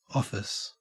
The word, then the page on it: office: Australian English